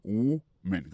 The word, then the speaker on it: woman: Par contre, si nous remarquons ça, m'a parlé là, m'a dit, a woman, doit parler de en fille au singulier, prononciation c'est a woman, quand on ou, son ou.